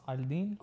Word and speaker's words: Aldin: Aldin.